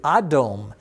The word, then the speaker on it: Adom: Now, in modern Hebrew, we say Adom, Adom, with an A instead of an E there. Adom, and that A is always pronounced as an A sound when you see it in Hebrew.